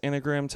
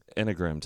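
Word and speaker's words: Enneagram: I think it's pronounced anagram test. It's anagram test.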